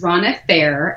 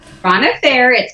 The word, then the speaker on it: Rhonna Farrer: Okay, so my name is Rhonna Farrer, and I have to say my name usually because people, when you read it, it looks like Rhonna Farrer or Rhonna Farrer or whatever. So when people finally hear me say it, they're like, oh, that's how you say it. So Rhonna Farrer, it's a little bit different, but I am a mother of three.